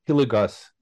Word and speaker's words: Hilligoss: Today it is my pleasure and a privilege to welcome Spencer Hilligoss, if I'm pronouncing it correctly.